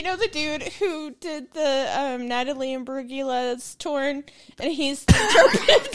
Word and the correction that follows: Imbruglia: It is Imbruglia. The G is silent. – It's what? – Imbruglia. Natalie Imbruglia. – That's what I said. – No, you had a G in there, a hard G. No, it is a silent G.